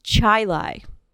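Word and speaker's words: Chili: And they're like, no, it's actually pronounced Chile.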